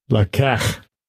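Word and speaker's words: Lakach (לָקַח): It looks like it's laka. Let me just do the audio, make sure I'm pronouncing it right. – Strong's H3947. – Lakach. Lakach. Lakach.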